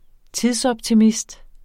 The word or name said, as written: tidsoptimist